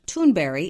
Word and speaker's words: Thunberg: Greta Thunberg, which is pronounced Thunberry in her native language, has just filed to trademark both her name and the Fridays for Future movement she created.